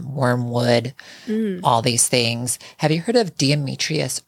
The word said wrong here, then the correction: diatomaceous: earth? – Yes. Diatomaceous earth? – Yes. – Yes. – I know exactly how you say it, but something like that.